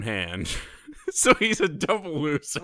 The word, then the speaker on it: Loeser: Daniel, and I want to say his last name is Loser. – How appropriate. – His last name is spelled L-O-E-S-E-R, so I'm going to call him Daniel Loser.